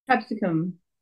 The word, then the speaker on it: capsicum: How do you say that word? – Capsicum. – Capsicum? – That's right.